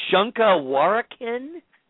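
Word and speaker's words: Shunka Warak'in: And then the other segment, now, please correct me if I'm pronouncing this incorrectly, Shunkawarakin? – Yeah, it's a Native American term that we just ended up sticking to.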